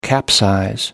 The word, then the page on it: capsize: American English